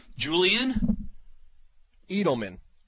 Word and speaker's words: Edelman: Julian Edelman.